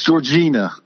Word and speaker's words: Georgina: No, don't get me started on Georgiana. – I am. It's Georgina.